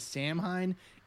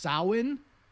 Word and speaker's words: Samhain: In that episode I said that the Irish festival of Halloween was known as Samhain. It's actually pronounced Samhain.